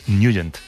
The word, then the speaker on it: Nugent: Pero la pronunciación correcta es Nugent. – Te fuiste a Washington y descubriste que era Nugent.